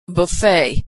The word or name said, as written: buffet